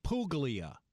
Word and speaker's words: Puglia: Right now, Chef Andrea is featuring the food of Puglia, which is spelled P-U-G-L-I-A. In other words, here in New Orleans, we would call it Puglia. But the Puglia, that's how they say it.